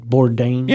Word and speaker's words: Bourdain: Did I say his name right? Bourdain? – Yeah. Yeah. You got it.